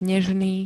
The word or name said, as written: nežný